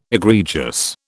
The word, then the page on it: egregious: British English